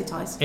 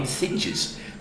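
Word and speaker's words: Thijs: Well, I'm going with Tice Van Leer. Tice, right, that's it. We'll say Tice.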